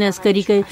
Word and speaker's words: عسکری: عربی میں تو گاف تلفظ ہے بھی نہیں کہ ہم امام حسن عسکری کو تلفظ عسکری کریں, عربی میں موجود نہیں ہے.